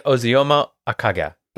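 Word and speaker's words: Ozioma Akagha: And Shuri is, I'm going to butcher this name, I'm so sorry, Osmia Akaga. How do you say it? – Looks like Ozioma Akaga.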